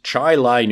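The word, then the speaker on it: Chili: So it is Chi Lai, New York, that Lincoln went to, passed through, and not Chile, New York, as I had said.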